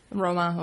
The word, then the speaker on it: Roma: Am I pronouncing that right? – Roma or Romani.